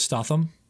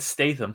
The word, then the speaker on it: Statham: Have you heard people pronounce his last name as Statham? I didn't know that. – No, that's weird. It's Statham.